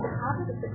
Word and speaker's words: Achab: que vous dites peut-être que vous prononcez peut-être Achab, en hébreu c'est Achab, aussi